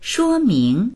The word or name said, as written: shuo1ming2